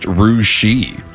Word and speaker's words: Russia: There's a guy in the chat saying Russia, Ohio is pronounced Rushi.